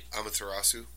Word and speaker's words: Amaterasu: So, and the Amaterasu was another one. I'm not pronouncing these gods' names right. – Amaterasu? – Yes. Well, that sounded a lot better than me.